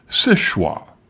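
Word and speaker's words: Seul Choix: According to Helga, the true French is séchoir, which means only choice. There are a number of theories about how the point got its name, but nowadays most locals pronounce it séchoir. So, as Helga pointed out, my pronunciation wasn't even close.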